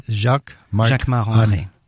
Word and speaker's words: Jacquemart-André: Jacquemart-André. Jacquemart Arnaud, you say it like André. A Frenchman. Jacquemart of course, because I'm French. So Jacquemart André.